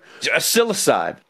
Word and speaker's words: psilocybe: Psilocybe. Yeah, well, the P is silent.